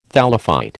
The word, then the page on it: thallophyte: American English